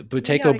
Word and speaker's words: Buteyko: One of the things that you did when you taped it was that, and I don't know if I'm pronouncing it right, the Buteyko breathing. – Oh, yes. That's, yeah, so, the Buteyko breathing.